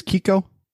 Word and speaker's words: Keiko: I say it as Kiko. It's K-E-I-K-O.